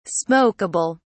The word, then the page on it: smokable: British English